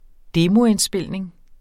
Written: demoindspilning

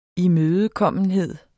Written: imødekommenhed

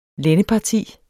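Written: LÆNDEPARTI

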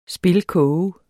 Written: spilkoge

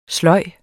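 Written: SLØJ

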